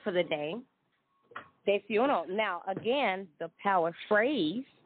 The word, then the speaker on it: desayuno: And if you're ready with your pen and paper, it's spelled D-E-S-A-Y. U-N-O, desayuno, or like D-say-uno.